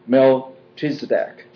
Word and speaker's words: Melchizedek: He has become a high priest forever in the order of Melchizedek.